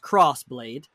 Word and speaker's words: Crossblade: It's called, you pronounce it Cross. It's like X Ross. – Yep. – But it's pronounced Crossblade. So Crossblade.